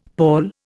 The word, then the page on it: pawl: Indian